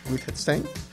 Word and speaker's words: Wittgenstein: Wittgenstein.